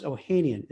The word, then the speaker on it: Ohanian: And Alex Ohanian, is that how you pronounce Ohanian? – Yes.